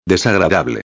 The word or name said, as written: desagradable